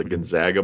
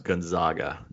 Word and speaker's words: Gonzaga: So I have an inside source that is going to the a basketball tournament down in Vegas where the Gonzaga Bulldogs are going to play. – Yep. – The Zags. And don't let any of your friends tell you that it's pronounced Gonzaga.